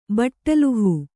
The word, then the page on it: ಬಟ್ಟಲುಹೂ: Kannada